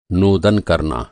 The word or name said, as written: नोदन